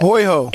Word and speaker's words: hoiho: Is that a hoiho? – I don't know. – Mispronounce the hoiho. Yeah, I guess that. – Hoiho. – H-O-I-H-O.